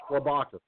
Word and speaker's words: Klobuchar: It's Klobuchar. – Klobuchar. – Okay. I call her Klobuchar. It's actually, I believe you're right, it's pronounced Klobuchar.